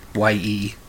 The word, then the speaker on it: Ye: The album, I guess, is called Ye, Y-E. It's pronounced Ye.